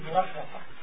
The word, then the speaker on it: غساقا: لاحظ السين غساقا. – He said, pay attention to the scene. The scene is a light scene. It's pronounced light here. Easy. Don't say غساقا with it being heavy.